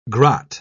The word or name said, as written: grot